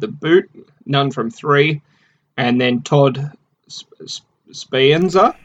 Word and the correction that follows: Sapienza: Is that how you uh, pronounce it? Sapienza. – Sapienza, one of two.